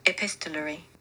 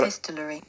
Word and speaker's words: epistolary: Epistolatory. Epistolon... Right, let's try this. – Epistolary. – Oh my god. – Epistolary. – Wow, yeah, I'd added a whole syllable in there.